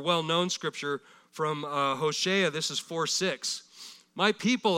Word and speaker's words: Hosea: It's actually pronounced Hosea.